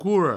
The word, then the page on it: quora: provençal; quouro